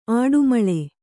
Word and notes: ಅಡಿಮಲೆ: aḍimale

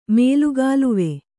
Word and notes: ಮೇಲುಗಾಲುವೆ: Kannada

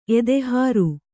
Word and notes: ಎದೆಹಾರು: edehāru

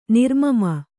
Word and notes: ನಿರ್ಮಮ: nirmama

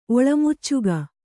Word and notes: ಒಳಮುಚ್ಚಗ: oḷamuccaga

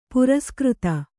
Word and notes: ಪುರಸ್ಕøತ: puraskřta

